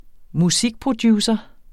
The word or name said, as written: musikproducer